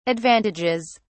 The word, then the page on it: advantages: British English